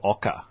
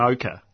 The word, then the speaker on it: OCA: And it was spelt O-C-A with an emphasis on the A. So it was sort of ochre.